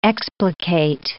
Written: explicate

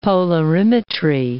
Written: polarimetry

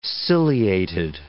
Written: ciliated

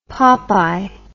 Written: popeye

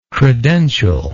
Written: credential

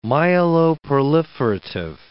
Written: myeloproliferative

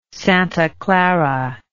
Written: Santa Clara

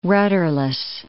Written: rudderless

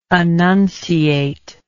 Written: annunciative

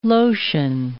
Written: lotion

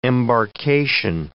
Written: embarkation

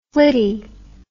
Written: flitty

